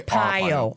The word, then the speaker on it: Arpaio: Well, what happened is that one of the detectives that worked for Arpaio, or Arpaio. – Arpaio. – Arpaio. R, like R. I would say Arpaio.